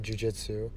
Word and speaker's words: jiu-jitsu: I can't pronounce that word right now. Jiu-jitsu. – That's good.